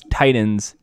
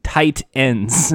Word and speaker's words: Titans: I like how I pronounce Titans, tight ends instead of Titans.